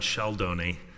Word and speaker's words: Sheldoni: It is exactly as it's spelled. It's not. Good morning, I'm John Sheldoni, and handling the rebuttal of Paragon.